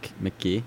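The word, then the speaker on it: Mk.gee: McGee.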